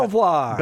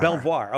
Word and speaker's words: Belvoir: You know, Belvoir. – Belvoir.